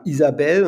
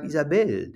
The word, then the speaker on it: Isabelle: Das Deutsche ist Pascal und, und die Franzosen sagen Pascal. Ja, wir sagen Isabelle und der Franzose sagt Isabelle. Der, der, der wartet ein bisschen, bis er die Betonung macht, weil er ne, die Kraft nach hinten raus nochmal verwendet.